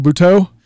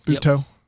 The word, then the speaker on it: Buteau: Michael Buteau. How you say it? That's right. Buteau. – Yep. – Kind of like Cajun. – Not butt out, but Buteau.